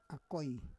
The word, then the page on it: akoi: Roon